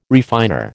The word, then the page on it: refiner: American English